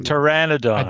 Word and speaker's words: Pteranodon: and then we have the Petir Pteranodon.